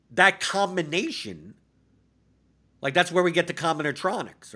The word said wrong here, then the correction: combinatorics: or combinatorics. I always say that wrong.